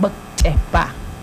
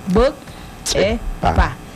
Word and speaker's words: Bukchepa: Claro, estamos listos, vamos a aprender cómo se escribe costilla en Yocotán y se pronuncia también Bukchepa. Bukchepa. Se conjuga con dos palabras, que es Buk, que es el hueso. Ichepa es costilla.